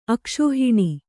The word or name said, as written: ಅಕ್ಷೋಹಿಣಿ